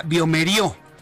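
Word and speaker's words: bioMérieux: También le informaré que el laboratorio Biomeriux Latinoamérica, bueno, la, la pronunciación sería Biomerio el laboratorio Biomerio Latinoamérica presentó en México la prueba PCR múltiple para detectar coronavirus o COVID-19 en tan solo 45 minutos.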